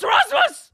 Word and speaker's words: Rasmus: So his name is Rasmus, R-A-S-M-U-S. Is that how you pronounce it? Like, I mean, if you saw that, would you go, that's what that is? – Rasmus? – Rasmus. – Sounds correct.